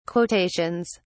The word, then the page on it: quotations: British English